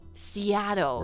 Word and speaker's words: Seattle: Seattle. – Right. – 我想说这个城市的发音是我觉得对于很多英语学习者啊有这种很夸张的要把嘴张得很大的发音我们觉得是很难的但是当你发西雅图的时候 you really need to open wide your mouth,、mm-hmm. right? Seattle.